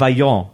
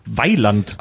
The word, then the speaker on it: Vaillant: Ja, und ich dachte mein Leben lang, die heißen Vaillant. – Aha. – Weil man das nur gelesen hat mit V, aber das wird deutsch ausgesprochen, Weiland.